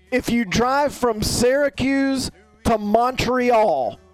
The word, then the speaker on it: Syracuse: By the way, I think you say Syracuse weird. I say Syracuse. You say Syracuse. – That's right. That's the right way to say it.